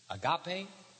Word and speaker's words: agape: A-G-A-P-E. Agape or agape as it is sometimes pronounced. A-G-A-P-E.